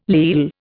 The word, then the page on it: leal: Indian